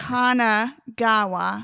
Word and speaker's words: Kanagawa: You introduced me to, well, I should say I picked off the shelf Kawagana. Is that how you pronounce it? – Is Kawagawa? There's no N in there. – Kanagawa.